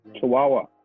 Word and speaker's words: Chawawa: How do you say that? – Chawawa.